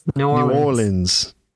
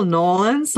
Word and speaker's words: New Orleans: How do you say New Orleans then? – New Orleans. Yes, yes. The more it just slides into like one syllable, Nolans, like some people just say Nolans, like Nolans, and it's okay. That's when they know that you're local. – But the stress there is on the or part, isn't it?